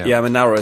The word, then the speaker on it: Manowar: Oh, that's how you pronounce it in Germany. I mean, we call it Manowar. Manowar is a correct pronunciation here in the States.